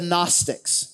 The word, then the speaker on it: Gnostic: The group of people that he was dealing with were a group referred to as the Gnostics, spelled with a G, like gnome. It's not gnome, it's gnome. This is spelled like Gnostics, but it's Gnostic, G-N-O-S-T-I-C, Gnostics.